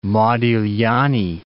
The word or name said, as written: Modigliani